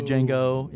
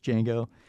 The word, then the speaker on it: Django: Because it's like Django Unchained, like that, where it's like, it's not the Django. You know, it's Django.